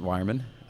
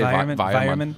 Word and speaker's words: Weyermann: And she works at Weyermann. Has the Did I say that right? – Yes. – Weyermann? – Well, they say Weyermann. Weyermann.